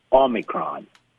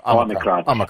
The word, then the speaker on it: Omicron: Probably the best way to pronounce it is Omicron, as if the O were A-W-E. – Omicron.